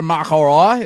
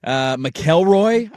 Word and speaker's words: McIlroy: They gave him the microphone and he mispronounced McIlroy's last name. – What'd he say? – McElroy, I believe. – McElroy. – And it was kind of slowed down, like, phonetically. McElroy.